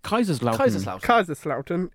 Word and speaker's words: Kaiserslautern: Kaiserslautern.